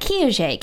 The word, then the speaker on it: Kiaxet: You can follow me on Twitter at Kiaxet, K-I-A-X-E-T.